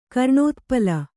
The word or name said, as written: ಕರ್ಣೋತ್ಪಲ